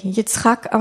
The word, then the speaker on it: Yitzhak: Das kommt wie Isaak, ja eigentlich Yitzhak ausgesprochen wird, von Zachak, das heißt lachen, scherzen, Spaß machen, sich belustigen.